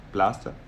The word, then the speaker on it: Blastar: Äh, B-L-A-S-T-A-R. Blaster. Blaster, genau. – Blaster.